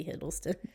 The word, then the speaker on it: Hiddleston: All right, now we have Tom Huddleston. Huddleston. – It's supposed to be Hiddleston.